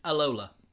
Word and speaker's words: Alola: It's Alola.